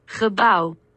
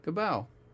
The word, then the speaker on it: Gebouw: This is at the North Sea Jazz Festival in the Congregebouw in The Hague, if that's the correct pronunciation of Gebou. I don't know. Let's find out what the correct pronunciation of that is. Okay. Translate Google. Let's detect language and then say Gebou. Building. It's Dutch, Gebouw. – Gebouw. – Gebouw. – Gebouw. Okay. Gebouw.